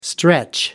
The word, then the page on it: stretch: American English